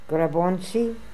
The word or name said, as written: Grabontzi